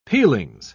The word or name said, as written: peelings